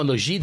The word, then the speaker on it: Gide: André Gide. No, Gide, that's right.